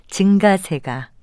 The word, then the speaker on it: 증가세: 이와 비슷한 예로 어떤 현상이 점점 늘어나는 흐름이나 경향을 나타낼 때 증가세로 발음하는 분도 있는데요. 이 경우에도 역시 예사소리로 발음하는 증가세가 맞는 발음입니다.